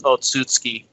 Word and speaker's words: Otsutsuki: So the and Sasuke and Boruto were left fighting the Otsutsukiya clan.